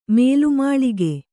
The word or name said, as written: ಮೇಲುಮಾಳಿಗೆ